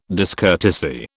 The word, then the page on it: discourtesy: British English